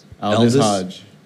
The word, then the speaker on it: Aldis: Aldis Hodge.